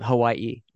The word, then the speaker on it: Hawaii: And I like that you pronounce it Hawaii, the proper name, because you're an island guy at heart.